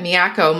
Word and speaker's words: MIACO: And I'll just interject real quick that MIACO must be how you pronounce the acronym of Music and Expressive Arts Center in Oklahoma. Did I get that right? – Yes, that's correct. Yeah, so MIACO for short, just because, you know.